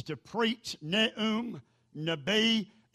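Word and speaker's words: Nabi (נביא): It is spelled N-A-B-I-Y, Nabi, Nabi.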